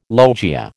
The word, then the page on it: logia: American English